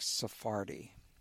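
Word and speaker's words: Sarfatti: there's someone you mentioned a while back, Jack Sefardi. Is that how you pronounce it? Yes.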